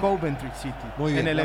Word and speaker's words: Coventry: Coventry. Acento en la primera. – Sí. Es Coventry.